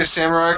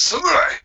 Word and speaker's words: samurai: Did you say samurai? Because it's pronounced samurai.